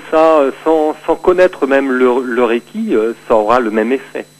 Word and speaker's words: Reiki: Donc, Reiki en japonais, c'est deux syllabes. Re, qui indique le caractère cosmique universel de cette énergie, et ki, force vitale essentielle.